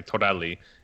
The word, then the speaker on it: Torelli: and he tried to pronounce my last name Torelli,